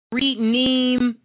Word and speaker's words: rhenium: This is the element that we're going to be talking about tonight, and it's spelled R-H-E-N-I-U-M. And this is on the element anatomic. It's the element number of number 775. But it is renemu.